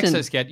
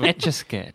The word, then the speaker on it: Etch-a-Sketch: What is it? Yeah, I keep saying exo, like exoskeleton. – You say exo-sketch. You said itchy sketch. – No, I didn't. Etch-a-Sketch.